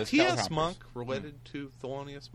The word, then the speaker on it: Thelonious: Who did Tabitha Soren deny referring to as the loneliest monk? – Because she's like misreading how to say Thelonious Monk.